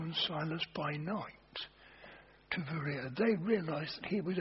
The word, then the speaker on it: Berea: In modern Greek, B is pronounced with a V. So they say, Berea, I